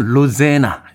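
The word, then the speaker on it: Rosanna: 토토의 로자나 들이었습니다. 노래를 볼 때는 로제나 이렇게 발음을 합니다.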